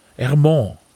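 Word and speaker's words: Hermance: Hermenance fahren. Wie wird das ausgesprochen? Ermont. Ermont. Ermont.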